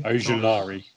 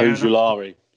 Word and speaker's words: Ojulari: Ojulari. – Yeah, Ojulari, yeah.